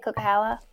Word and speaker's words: Coquihalla: Coquihalla? – Yes.